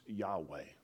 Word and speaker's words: Yahweh: YHVH, many of you who may know this, it is the symbolic name of God, pronounced Yahweh.